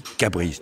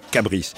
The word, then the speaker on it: Cabris: la prononciation du maire de Cabris, qui dit Cabris. Donc on dira Cabris,